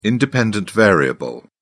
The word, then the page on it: independent variable: British English